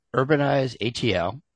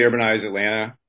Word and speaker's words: UrbanizeATL: Today, we've got UrbanizeATL. We're going to talk about what's going on there. Am I saying it right? Should I say Urbanize Atlanta or Urbanize ATL? – I usually say Urbanize Atlanta.